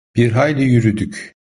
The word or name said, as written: hayli